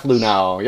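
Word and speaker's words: Luhnow: Because apparently, if you read both of the statements from Hinch and Luno, that, you know, am I saying that right? – Jeff Luno.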